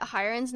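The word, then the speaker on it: Haerin: Random, but then, did you know that Hyerin's name is not pronounced that way? It's Hyerin.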